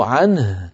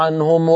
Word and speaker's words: عنه: اسمعني سأقلدك، رضي الله عنهم ورضوا عن ماذا سمعت؟ آخر حرف سمعته من نطقي ما هو؟ النون. أين الهاء؟ لابد أن نقول ورضوا عنه، عنه عين نون هاء عنه، فنبين حرف الهاء، وإلا نكون قد أنقصنا من التلاوة حرفا طبعا عن غير تعمد.